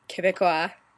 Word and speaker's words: Québécois: I live in Montreal, Quebec, and therefore I am Québécois. That is how you pronounce it. Québécois. Allison was right, even though the emphasis was on the wrong syllable.